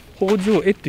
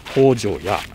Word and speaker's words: 放生会: 治になってえ新政府が神仏の分離策取りますと中秋祭というふうに改められたんですけれども福岡の方では今も変わらず北条絵、えっと、放つ生きる会うと書きまして北条絵という読み方なんですけれども博多弁では北条やになっております